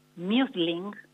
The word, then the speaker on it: mulesing: Para evitar esta enfermedad se les aplica una práctica que se denomina muesling, se escribe mulesing, por si lo quieren buscar y googlear ustedes mismes.